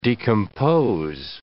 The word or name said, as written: decompose